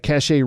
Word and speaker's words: Cachet: She is a residential mortgage loan originator at Cachet Real Estate Finance, powered by Mortgage Financial Services. Did I say that right, Jackie? – Yes.